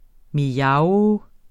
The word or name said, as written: mjav